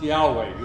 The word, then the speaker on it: Yahweh: Later scholars say that this name of God could maybe be better pronounced Yahweh.